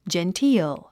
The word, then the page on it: genteel: dʒentíːl; ジェンティール